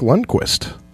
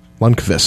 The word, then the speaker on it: Lundqvist: Henrik Lundqvist. Lundqvist, sorry. I've been saying it wrong.